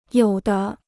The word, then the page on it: 有的: yǒu de